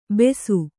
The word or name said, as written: ಬೆಸು